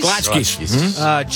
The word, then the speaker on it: Kolachki: Kolachki, is that how you say it? Kolachkis? – Kolachkis.